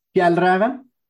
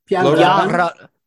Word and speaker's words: Fjällräven: Fjällräven. – Excuse me. Say that again. – Fjällräven. – Fjällräven.